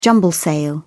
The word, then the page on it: jumble-sale: British English